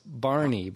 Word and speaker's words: Bjarni: It's Barney, but it's spelled B-J-A-R-N-I. Bjarni.